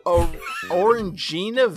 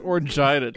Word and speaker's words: Orangina: And John Harrington said Orangina Vision? – It's pronounced Orangina, David.